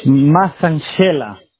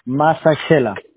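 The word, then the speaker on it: Massashela: S-S-A. – S-H-E. – S-H-E. ¿Cómo se dice tu nombre, oye? Ortiz, ¿cómo? Macet. – Masanchela, pero falta la L y la A, así está en la tarjeta, solo quedaron en la Che. Masanchela. – So, M-A-S-S-H-E. – Sí. – M-A-S-S-A-S-H-E.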